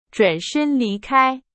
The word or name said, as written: 转身